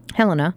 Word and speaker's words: Helena: And rather than spending $20,000 on a bomb dog, the Helena Police Department, am I pronouncing that right? – Yes. – Okay. Helena,